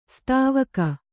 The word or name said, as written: ಸೇದೆವುಗು